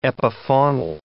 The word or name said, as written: epifaunal